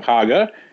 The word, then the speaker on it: Hager: Do you think that there is a strong correlation between misspelling of Hager and your approval or disapproval of his book? Because like everybody who dislikes it spells it Hagar, H-A-G-A-R. Anyone who likes it spells it correctly, H-A-G-E-R, rhymes with lager.